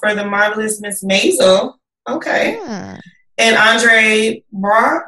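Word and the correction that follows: Braugher: Brower, thank you.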